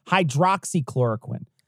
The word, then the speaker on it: hydroxychloroquine: And it's her saying that, you know, COVID is the hydro, it's basically a plug for hydrochloroquine. – Right. – Or hydro, is it hydroxychloroquine? – I always say it wrong. Hydroxychloroquine.